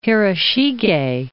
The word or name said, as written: Hiroshige